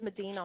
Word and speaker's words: Medina: Medina. – Yes. So how can I help you? – How did you say your last name? – My last name is Medina.